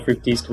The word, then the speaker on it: Fructis: And The Transplants' music was featured in commercials for Neutrogena, Garnier Fructis shampoo. I'm sure I said that wrong. – Fructis. I remember that.